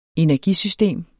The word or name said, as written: energisystem